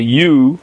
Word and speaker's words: ewe: And then we have kvulos, which means that the rachel, which is in fancy English called a U, that's spelled E-W-E, can go out kvulos, which means aliyosan kshura lamata.